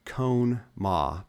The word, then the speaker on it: Conemaugh: and it's spelled Conemaugh, but it's pronounced, I believe, Conemaugh, after several days of heavy rain.